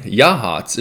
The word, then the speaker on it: Yachats: For all of our listeners outside of Oregon, Yachats is a place on the Oregon coast, but it is spelt Yachats.